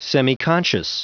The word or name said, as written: semiconscious